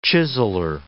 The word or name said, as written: chiseller